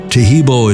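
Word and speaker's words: Taheebo: Tehebo is spelled T-A-H-E-E-B, like boy, O, then the word T, and then the word club.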